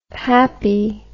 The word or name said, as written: pappie